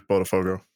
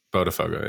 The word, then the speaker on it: Botafogo: So the latest on Cuellar, and we've seen this dispelled a couple times, but a new report today that Botafogo, well, I don't know if that's how you pronounce it. – But Botafogo. – No, I'm pretty sure it's Botafogo. – Botafogo, yes.